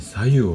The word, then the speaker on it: 白湯: と白いお湯って書いて左右って読みますけども左右の効果と作り方そして飲み方こんな話題についてお話をさせていただこうと思っていますで健康のために左右